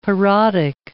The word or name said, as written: parodic